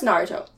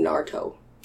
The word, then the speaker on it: Naruto: It's pronounced Naruto. – Naruto? – When you watch the like subs, it sounds like they're saying Naruto. – Okay. – Like N-A-R-T-O.